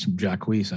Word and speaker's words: Jacquees: I think I was pronouncing his name wrong previously. My wife told me it's Jacquees.